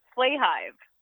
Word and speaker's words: Flahive: It's it's Flahive.